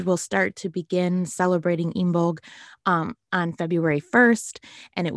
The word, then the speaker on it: Imbolc: Another fun little tie-in to the theme of balancing is that Imbolc, or you may hear it pronounced as Imbolc, but it's Imbolc, is in February.